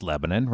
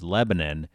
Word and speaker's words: Lebanon: But people from out of state always think we're saying it wrong because the country is pronounced Lebanon, right? – Right.